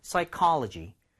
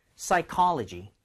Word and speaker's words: psychology: 근데 여기 P는 묵음입니다. 네, 이게 이제 심리학이고요. CHO, O에다 강세 하나 찍어주세요. 그러면 call일까요? car일까요? – car이네요. – Yes. 자, 두번 들려드릴게요. 강세를 가운데 넣어가지고 psychology, psychology.